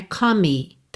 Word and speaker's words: come: Come, outra palavra que tem o E final mudo, então não é come, tá? Come, o som termina no M.